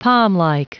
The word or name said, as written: palmlike